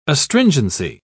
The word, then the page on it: astringency: British English